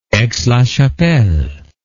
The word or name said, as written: Aix-la-Chapelle